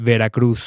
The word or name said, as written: Veracruz